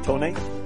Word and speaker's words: Tony: Tony. – Tony, can you spell that? – T-O-N-Y.